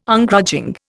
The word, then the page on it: Ungrudging: Indian